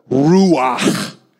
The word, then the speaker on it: ruach: Uh, another term that's used is the ruach, which is how you say it in Hebrew, which just sounds like like a wind blowing out.